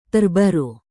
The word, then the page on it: terbaru: indonesian